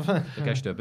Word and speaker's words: Takeshita: Takeshita.